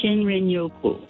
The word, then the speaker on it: shinrin-yoku: I think it's called shin-yoku. Is that how you pronounce it? The Japanese term for wood air bathing. – Shinrin-yoku.